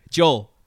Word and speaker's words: Joel: Joel. Like Billy Joel.